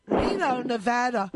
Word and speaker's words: Reno, Nevada: And Reno, Nevada, I got to say it right. Reno, Nevada. – That's perfect.